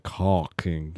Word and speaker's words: caulking: What's really weird if you pronounce it properly, it doesn't sound like cock. It sounds like caulking, right? – Yeah, whatever.